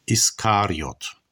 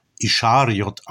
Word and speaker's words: Iskariot: In unserer lutherischen Tradition wird er oftmals auch Ischariot ausgesprochen. Aber das hängt damit zusammen, dass ein deutsches SCH ein Sch ist, aber wenn es aus dem Griechischen kommt, ist ein Sigma und ein Chi, das deutsch wie ein SCH übersetzt wird, doch nur ein SK, Iskariot.